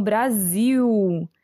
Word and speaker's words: Brasil: É, mas é, pegando essas expressões, só para vocês é, notarem que a gente não pronuncia esse L no final das palavras como uma consoante. O som é de U. Então, Brasil, né?